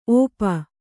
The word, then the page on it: ಒಪ್ಪ: oppa